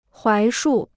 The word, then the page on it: 槐树: huái shù